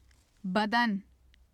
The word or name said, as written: badan